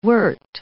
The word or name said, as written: wert